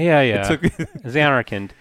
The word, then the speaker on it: Zanarkand: He's from Dream Zarkan Land. I, am going to say it wrong each time. So there's a real Zarkan Land way back in the day, and Zanarkand. Zanarkand. Jeez.